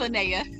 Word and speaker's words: Linnea: Yes, it's Linnea.